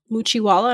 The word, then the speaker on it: Muchiwala: Your own roots are in a tribal and native community in Pakistan called Muchiwala. Am I pronouncing that right? – Mm-hmm.